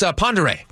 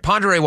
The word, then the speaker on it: Ponderay: It's Ponderay. I'm sorry, Ponderay, Washington. That's how it's pronounced. Ponderay.